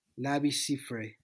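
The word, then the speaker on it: Labi Siffre: Pues esa rola tal vez te refieras a este cantante que también era poeta, Lavi Cifre. – No sé cómo. Ese mismo. – Ajá. – Me parece que es así la pronunciación correcta.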